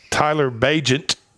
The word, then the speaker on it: Bagent: Tyler Bagent, and then you pronounce his last name like agent with a B in front of it, played at Shepherd's University.